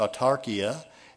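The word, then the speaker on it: autarkia (αὐτάρκεια): Well, the Greek word for there is pronounced autarkia, and it says a competency, a sufficiency, that portion which is necessary for the support of life.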